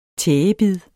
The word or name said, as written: tægebid